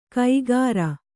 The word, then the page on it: ಕೈಗಾರ: kaigāra